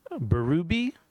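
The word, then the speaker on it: Bérubé: And then for the World War II stuff, I read a book called Coming Out Under Fire by Alan Berube, is how I think we're pronouncing his last name, or I've been told to pronounce his last name. There's an accent mark. It's French-Canadian, I think.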